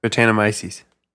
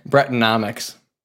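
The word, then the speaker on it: Brettanomyces: How do you pronounce it again? – Tetanus. – No, that's not right. – Brettonomics. – Brettonomics. – It's like economics.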